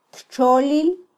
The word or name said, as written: ttxolil